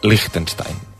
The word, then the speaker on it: Liechtenstein: ¿Lo he dicho bien? Liechtenstein. – El segon intent ha estat millor. És molt important tenir un bon servei d'assessorament lingüístic que et permet saber que aquest país es pronuncia Liechtenstein.